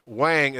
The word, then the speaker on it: Wang: Garrett, I just stumbled right all over his name because I always, in my head, you know, I was like I'm going to say it wrong, and then I say it wrong, right? Garrett Wang is going to be on,